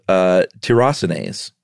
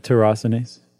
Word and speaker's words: tyrosinase: How would you pronounce it? – Just exactly like that, tyrosinase. – Oh, okay. That was tyrosinase. But that's how it's spelled, tyrosinase. But tyrosinase surely is the way that you'd say it, right? – I think probably so.